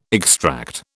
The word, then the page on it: extract: British English